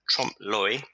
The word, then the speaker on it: trompe l'oeil: It's trompe l'oeil, I think it's supposed to be pronounced. My French isn't that good. It means literally deceive the eye.